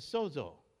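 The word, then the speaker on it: Sozo (σῴζω): The word that they translate into well or whole, let's go to the King James word, whole, is sozo. S-O-Z-O with a long O sound on both O's. Sozo.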